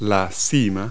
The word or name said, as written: peak